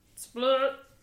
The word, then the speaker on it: SPL-T: I don't know how to say it. SPL-T. Split-T. – Split. – Which is by...